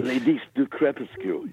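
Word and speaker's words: Les Disques du Crépuscule: And then the big Picture Sucks, which is back on, the Belgium label, which I'll mispronounce. But anyway. – Ladies du Crepuscule. – Beautifully, yes. That's always good.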